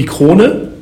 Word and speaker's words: Krone: The German pronunciation would be Krone. – Krone. – But of course, due to the fact that we are selling in more than 60 countries, many of them are speaking English. If they do the communication and with us, and therefore the English pronunciation Krone is also completely fine.